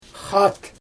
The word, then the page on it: xaat: Xyzyl